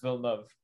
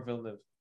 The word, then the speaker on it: Villeneuve: It's literally pronounced Villeneuve or Villeneuve.